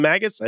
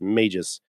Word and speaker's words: Magus: And yes, that's how I'm going to pronounce it for the remainder of the stream. Go ahead and at me. I'm not calling him Magus. I don't care what you say. It's Magus because they're magicians, not magicians.